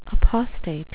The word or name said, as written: apostate